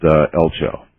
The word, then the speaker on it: Eltjo: It's spelled E-L-T-J-O, but pronounced Elcho.